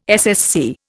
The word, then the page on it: SSC: Indian